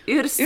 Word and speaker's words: Yrsa: Yrsa, Yrsa säger man på finlandssvenska.